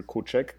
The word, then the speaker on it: Kuczek: My name is Aleksander Kuczek, K-U-C-Z-E-K.